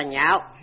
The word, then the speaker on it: nhạo: Chữ này đọc âm cao, chẳng đọc lạc mà đọc là nhạo, là nghĩa ưa thích, nghĩa hy vọng.